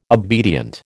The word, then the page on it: obedient: American English